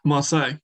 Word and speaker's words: Marseille: Marseille? – Marseille, yeah.